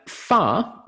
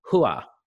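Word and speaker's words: Hua (花): Fa is a Cantonese pronunciation of the Mulan family name. In Mandarin, and I apologize as a non-Chinese speaker if I mangle any of these pronunciations, but in Mandarin, the correct pronunciation would be Hua, which means flower.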